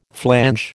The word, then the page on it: flange: American English